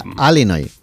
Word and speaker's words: Illinois: Se pronuncia Illinois. – Sí, perdón. ¿Perdón? Sí. – Illinois. – Pues yo digo Illinois. Y no entendemos todos. Porque como diga Illinois, nadie lo entiende.